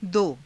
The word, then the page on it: do: hindi